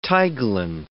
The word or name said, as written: tiglon